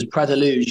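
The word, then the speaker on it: Praia da Luz: Yeah, well, first of all, Ben, should I say congratulations on your pronunciation, because Pras de Luge, you said it very well.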